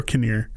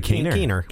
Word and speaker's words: Keener: Catherine Keener or Kinnear. How do you say it? – I would say Keener.